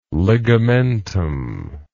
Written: ligamentum

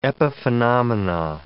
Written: epiphenomena

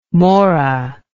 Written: maura

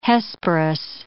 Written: Hesperus

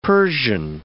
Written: Persian

